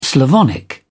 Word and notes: slavonic: British English

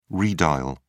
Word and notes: redial: British English

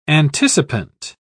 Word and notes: anticipant: British English

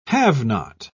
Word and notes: have-not: British English

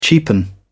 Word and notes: cheapen: British English

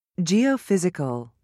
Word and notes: geophysical: British English

